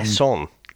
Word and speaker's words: Besson: It was trying to be too clever, because obviously, I'm going to say his fucking name, Bison. Is it B? What is it? I keep saying it wrong. – Besson. – Besson. Right, Besson, that's him as well.